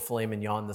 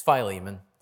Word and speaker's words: Philemon: Tom said filet mignon this morning. It's not how it's pronounced. Just so you know, it's Philemon.